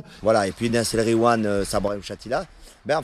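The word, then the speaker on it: Nass El Ghiwane: Nas El Gwiam. Voilà. Et quand il y a GH, tu dis re. Tu fais comme si c'était un R.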